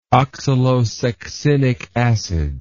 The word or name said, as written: oxalosuccinic acid